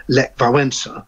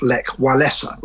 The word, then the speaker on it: Lech Wałęsa: And I think that it's right to call the Polish opposition leader Lech Wałęsa and not Lech Walesa, which is sort of the English pronunciation of it.